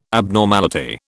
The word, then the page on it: abnormality: British English